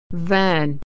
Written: van

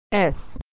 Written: S

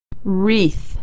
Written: wreath

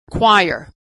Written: choir

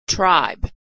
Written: tribe

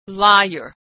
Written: liar

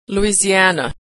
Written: Louisiana

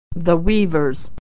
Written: weavers

